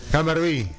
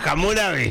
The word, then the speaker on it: Hammurabi: No era Hammurabi. – Lo pasa es que él lo pronuncia en inglés. Que lo entienda, polla. – Hammurabi. – Él lo pronuncia en inglés. – Hammurabi. – Hammurabi. ¿Qué hace? – ¿Qué hace? Sí. – Los ingleses decían Hammerby. El tipo dice Hammerby. Él lo vio así en Discovery y en Discovery.